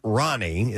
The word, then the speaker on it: Rani: A girl named Ronnie. It's spelled R-A-N-I, but it's pronounced Ronnie.